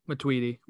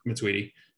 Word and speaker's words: Matuidi: Because if this happens to Tootie, is that how you say it? Matuidi. Matuidi.